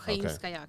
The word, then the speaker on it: Haimskaya: In Russian, Haimsky, yeah. Well, Haimskaya, because I'm a girl.